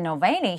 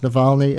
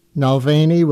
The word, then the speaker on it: Navalny: Never mind that she mispronounced Navalny as Nalvaney.